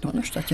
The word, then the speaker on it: Donaustadt: Donaustadt, ja.